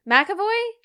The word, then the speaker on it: McAvoy: McAvoy? – Yeah.